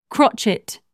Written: crotchet

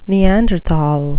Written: neanderthal

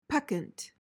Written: peccant

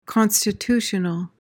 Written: constitutional

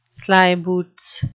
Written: slyboots

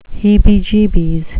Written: heebie-jeebies

